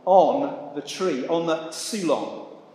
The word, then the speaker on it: xulon: It's spelled X-U-L-O-N. And I'm not very good at my pronunciation. It's probably xulon, something like that.